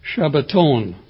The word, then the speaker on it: Shabbaton: They pronounce them Sabbaton, not Shabbat. Shabbaton.